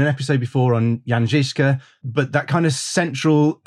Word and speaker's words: Žižka: You'll be able to say Žižka, which very good pronunciation there.